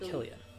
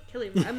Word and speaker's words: Cillian: You mean Killian? Killian. Yeah, sorry, Killian.